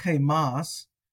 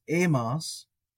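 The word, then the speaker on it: emas (ἡμᾶς): Maybe just to remind folks that the eta with the rough breathing mark is pronounced smoothly in Koine, so not chemas, just emas, although that would add to the guttural effect if we were doing a rough breathing.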